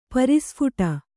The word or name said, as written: ಪ್ರಸ್ಫುಟ